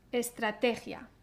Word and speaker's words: estrategia: Y la última sílaba es gia, gia, gia. La i y la a se pronuncian dentro de la misma sílaba. Entonces, atención a no romper esta sílaba. Por ejemplo, gia, ¿no? Se pronuncia dentro de la misma sílaba. Gia, gia, gia. El sonido j se pronuncia detrás en la boca como en la palabra Javier. Javier. Entonces, estrategia. Ta tata, Estrategia.